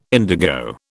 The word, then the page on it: indigo: British English